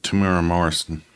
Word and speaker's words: Temuera Morrison: You know, the actor, how you say his name? – He's one of your favorite actors, right? – Temuera Morrison.